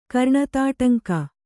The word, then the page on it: ಕರ್ಣತಾಟಂಕ: Kannada